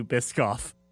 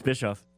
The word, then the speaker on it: Bischoff: I remember a media appearance, Tyler, where they kept calling you Biscoff. – Yeah, it happens. I probably wouldn't do it. – The entire media thing, and I kept screaming, it's Bischoff. It's Bischoff.